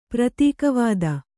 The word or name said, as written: ಪ್ರತೀಕವಾದ